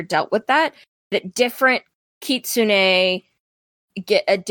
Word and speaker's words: Kitsune: Also, you'll hear it pronounced a lot of different ways. Kitsune is what I've always called Ketsune. What was the one you were talking about, Chad? – I heard Kitsween.